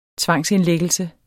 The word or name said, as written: tvangsindlæggelse